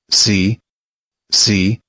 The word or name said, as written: see